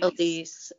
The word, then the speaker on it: Elise: So Nancy O'Mallon, who is the vice chairman of the Bergen County Lee Film Commission, said, quote, her name is spelled Alice, so I keep having to catch myself, but it's pronounced Elise. – Elise.